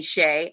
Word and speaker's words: Shā: And a friend of mine from high school wrote my name out, called me Shay. And he wrote the, I think it's called a micron or a dichron across the A for the long A.